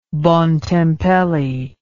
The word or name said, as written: Bontempelli